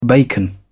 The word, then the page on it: bacon: British English